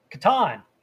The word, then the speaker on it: Catan: How do you pronounce Klaus Teuber's The Settlers of Catan? – There we go.